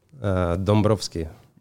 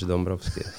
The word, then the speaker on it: Dombrovschi: Dombrovski. – Yeah, you want the that's actually thing? A little bit. It's Dombrovski, right? – Evgeny Vyacheslavovich Dombrovski.